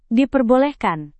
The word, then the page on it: diperbolehkan: Indonesian